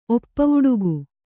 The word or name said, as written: ಒಪ್ಪವುಡುಗು